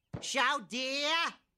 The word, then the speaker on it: chowder: Chowder. It's Chowder. Say it right.